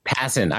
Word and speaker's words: Passan: ESPN's Jeff Passan. Is that how you pronounce it? – Passan.